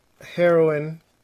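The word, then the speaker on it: Heroine: Uh, it's called Heroin, but it's like, it's spelled heroin, like the drug, and then it's got a posh like a parentheses e, end parentheses like heroin, like the female hero.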